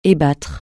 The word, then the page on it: ébattre: francais